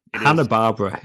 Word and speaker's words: Hanna-Barbera: Is it Hanna-Barbera or Hanna-Barbera? Whichever one. – Hanna-Barbera, yeah. – It is Hanna-Barbera? Right.